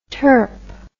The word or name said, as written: terp